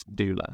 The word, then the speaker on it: doula: So, when we're doing our sort of background on you, we saw you used the term business doula.